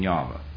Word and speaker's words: Yahweh: And so consequently, they then, as well as today, will not use the name Jehovah, or the perhaps more correct pronunciation, Yahweh.